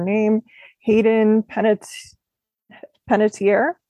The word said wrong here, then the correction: Panettiere: Hayden Panettiere, who she was in the TV show Nashville mm-hmm.